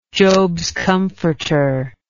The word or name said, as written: jobs comforter